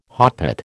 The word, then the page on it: hotpot: American English